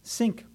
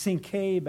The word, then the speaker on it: Cinque: It might not be much, but the name of the ship that Alexander Selkirk was on was spelled C-I-N-Q-U-E, and then the word ports. And in English, that's pronounced sink ports. Now, anyone who's a sailor and takes a commission on a ship named Sink has to have judgment questioned, I would say. Would you? Sink ports. Now, they say, well, maybe it was actually pronounced Sinké back then, and it was actually the number five on the dice was called the Sink or the Sinké.